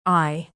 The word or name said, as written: eye